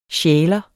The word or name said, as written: sjæler